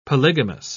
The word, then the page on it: polygamous: British English